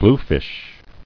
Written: Bluefish

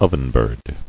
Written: Ovenbird

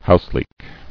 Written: Houseleek

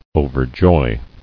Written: Overjoy

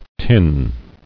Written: Tinned

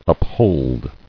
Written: Upholder

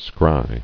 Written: Scry

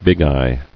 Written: Bigeye

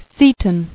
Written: Seton

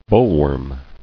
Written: Bollworm

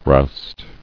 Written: Roust

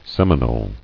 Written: Seminoles

